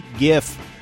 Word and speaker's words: GIF: Point for Marketplace's Kai Rizdal, the CEO of Adobe, uses a hard G, GIF. I'm still a soft G kind of guy. I know it's wrong, but it feels so right.